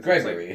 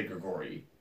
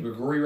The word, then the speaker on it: Grigori: It's like, it was somebody who was, I think, Gregory. – Like somebody who like... – Yeah, but it's Russian, so they say Grigori. – Oh, okay. – Yeah. That's how you say his name, Grigori Rasputin.